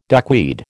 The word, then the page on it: duckweed: American English